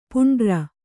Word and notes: ಪಾಂಡುರ: kannada

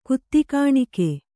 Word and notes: ಕುತ್ತಿಕಾಣಿಕೆ: kannada

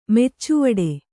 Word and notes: ಮೆಚ್ಚುವಡು: meccuvaḍu

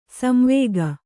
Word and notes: ಸಂವೇಗ: samvēga

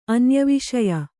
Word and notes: ಅನ್ಯವಿಷಯ: anyaviṣaya

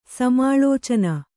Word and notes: ಸಮಾಳೋಚನ: kannada

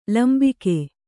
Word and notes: ಲಂಬಿಕೆ: lambike

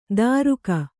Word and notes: ದಾರುಕ: Kannada

